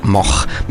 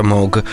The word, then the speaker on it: Moog: Nei Paesi Bassi si pronuncia Moch, mentre in Germania, dove si trova spesso, comunque si pronuncia Moog. I paesi anglofoni vedono la doppia O e lo pronunciano Moog, ma lo stesso Bob, il più famoso forse di quelli che portano questo cognome, preferiva la pronuncia tedesca.